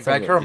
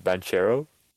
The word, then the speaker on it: Banchero: Was I the only one disappointed that it wasn't pronounced Banchero? – I think Bankero is pretty goddamn cool, bro. – Yeah, I thought Bankero was solid. – I was disappointed. I thought it was like Banchero.